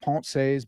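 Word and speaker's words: Pensées: It's Parker's Pensies. Started because I had a blog by the same name. I know it's pronounced Ponce's, but I'm an American swine.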